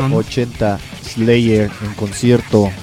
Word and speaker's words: Slayer: Slayer. – Slayer. – Su mejor pronunciación.